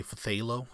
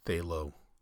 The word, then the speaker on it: phthalo: So originally I was going to say phthalo blue. But I know that Bob Ross calls these phthalo. I think he uses phthalo white.